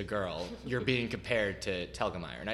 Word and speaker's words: Telgemeier: Telgemeier.